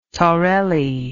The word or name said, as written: torelli